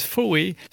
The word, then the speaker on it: Faux: Bonjour, Contrucho sur WRCF Country avec un instant King Faux, F-A-U-X, mais en fait ça se prononce Faué.